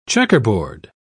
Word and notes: checkerboard: British English

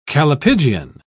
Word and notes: callipygian: British English

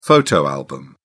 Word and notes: photo album: British English

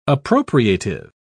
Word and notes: appropriative: British English

